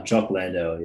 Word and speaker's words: Jock Landale: Is his name Jack Lawndale? – Jock Landale, yeah.